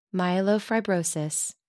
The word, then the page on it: myelofibrosis: American English